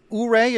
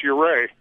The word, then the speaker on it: Ouray: It's Ouray.